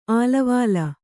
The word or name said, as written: ಆಳವಾಳ